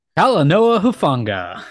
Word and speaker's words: Talanoa Hufanga: Talanoa Hufanga.